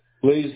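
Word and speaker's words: Blase: B-L-A-Z-E. – Blaze DeRocco. – Okay, B-L-A-Z-E or C-E? – It's pronounced like a fire, an S instead of a Z. – Oh, okay. – Blaze is how it's pronounced. But it's B-L-A-S-E. – Got it. It's B-L-A-S as in Sam, E as in Echo.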